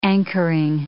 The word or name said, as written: anchoring